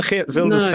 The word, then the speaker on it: Geert Wilders: And on the Geert Wilders thing, one of the interesting things is that in the past, when his party good pronunciation, very good.